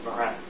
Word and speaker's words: Marin: We're in the town of Marin, M-A-R-I-N. – Very convenient. – M-A-R-I-N.